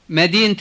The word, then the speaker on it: مدينة: And remember that when you say the city of such and such, the word Medina has a T at the end. Repeat. – Medina Riyadh. مدينة الرياض.